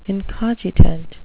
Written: incogitant

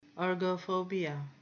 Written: ergophobia

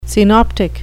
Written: synoptic

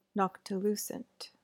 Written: noctilucent

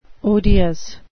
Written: odious

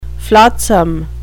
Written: flotsam

